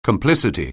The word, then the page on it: complicity: anglais